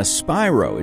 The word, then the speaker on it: Spiro: And remember, when you're trying to Google her, it's Sienna Spiro. It's spelled S-P-I-R-O, but it's pronounced like that dragon.